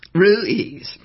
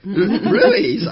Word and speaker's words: Ruiz: In Spanish, it's Ruiz. – I can't say that. – In English, we pronounce it Ruiz. – Ruiz. Okay, like Suiz. Ruiz.